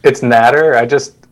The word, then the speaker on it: Nader: It's Nader. I always tell people, like, what's the matter?